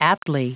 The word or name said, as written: aptly